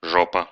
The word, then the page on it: жопа: [ˈʐopə]; žopa